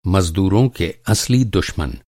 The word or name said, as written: दुश्मन